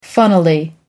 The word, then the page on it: funnily: American English